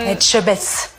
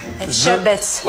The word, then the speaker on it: Etchebest: Etchebest. – Best. – Best. – The best. – Je best. Etchebest.